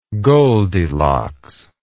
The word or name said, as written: goldilocks